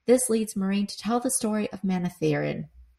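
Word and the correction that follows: Manetheren: This leads Meereen to tell the story of Manethrin, the ancient city where Edmund Field is.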